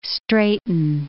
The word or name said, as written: straighten